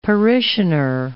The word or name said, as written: parishioner